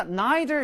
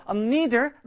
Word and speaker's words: neither: 자, 선생님이 아까 neither 이렇게 읽어드렸는데요. neither, neither. 둘다 발음 가능한 방법인데요.